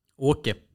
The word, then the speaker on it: Åke: Åke. – Åke, ja. Å, säger man så? Åke? – Jag gör. – Du säger Åke. Svenskt uttal.